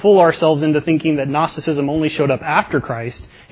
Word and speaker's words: Gnosticism: And so there were some really, really early who believe that this is Paul actually combating Gnosticism. Sorry, tripped over the word. Always want to pronounce that G. Gnosticism.